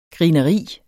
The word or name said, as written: grineri